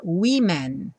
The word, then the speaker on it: women: Também a última sílaba é que muda, ao invés de woman com A, a gente escreve com E, mas curiosamente a pronúncia muda no começo e a gente fala women no plural.